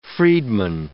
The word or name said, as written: freedman